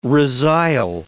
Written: resile

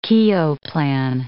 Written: Keogh plan

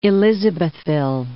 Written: Elisabethville

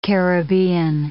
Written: Caribbean

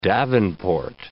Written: davenport

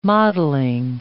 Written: modelling

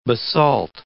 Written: basalt

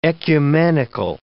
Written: ecumenical

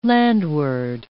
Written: landward

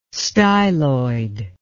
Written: styloid

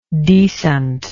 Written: decentness

